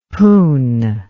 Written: poon

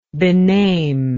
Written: bename

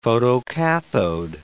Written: photocathode

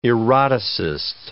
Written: eroticist

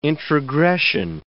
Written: introgression

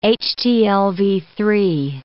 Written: HTLV-III

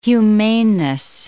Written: humaneness

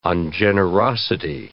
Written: ungenerosity

